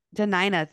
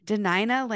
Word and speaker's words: Dena'ina: She was born in Portland, Oregon, but is proud to call, I'm going to say this totally wrong, Denai, is that how you say it? – Denina, thank you. Denina lands in Anchorage, Alaska, home now.